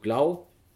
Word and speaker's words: glaw: GLAW geschrieben, wird aber ähm, als Glau ausgesprochen, bedeutet Strahlung.